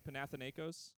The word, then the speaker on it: Panathinaikos: I never know how to pronounce this name, but it's Panathinaikos.